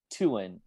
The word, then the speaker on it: Tuan: Tuan. And, you know, I think that's the closest to the Vietnamese pronunciation. It's much closer than Tuan, you know, so I think a lot of people in the States are more accustomed with, you know, Spanish style of pronunciation because of the word Juan.